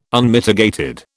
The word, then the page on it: Unmitigated: British English